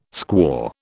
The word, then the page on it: squaw: British English